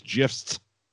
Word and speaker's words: GIF: I sent you the GIF of Sandman's GIF. Getting jerked. GIF. – You don't send GIFs, do you, on Christmas? You don't have Christmas GIFs. – GIF. – GIFs. – No, it's a soft G.